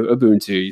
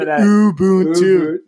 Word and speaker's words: Ubuntu: Ubuntu. You said it. – I got it. I even wrote literally here, it's phonetically on my notepad. No one can see. – Let's see I did Ubuntu.